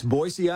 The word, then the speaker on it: Boise: People always say it with a Z, right? – That's right. It's Boise, Idaho.